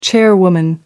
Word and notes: chairwoman: American English

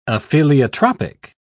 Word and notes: apheliotropic: British English

